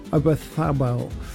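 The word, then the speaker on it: Oyarzabal: Defence, Rodri and Ruiz, Lamine, Yamal, Olmo, Bayena and Ozabalath. Is up front? I didn't say that right, did I? I know. Obathabel.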